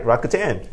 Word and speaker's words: Rakuten: Well, you got it right, Rakuten.